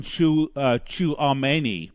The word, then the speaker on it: Tchouaméni: And they're also looking for Aurelien Tchouaméni. And I actually got his name right. I always say it and it sounds like I'm chewing tobacco.